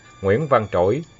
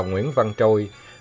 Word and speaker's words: Trỗi: Bây giờ nghe nhắc, nó mới bật ra và nhớ lại Nguyễn Văn Trỗi mà đài phát thanh Hà Nội đọc là Nguyễn Văn Trôi.